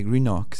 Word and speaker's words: Greenock: Alors Airdrie, Coatbridge et Greenock. C'est ça, je les prononce sans doute assez mal, mais je vais vous laisser le faire peut-être. – Non, non, c'est très bien prononcé.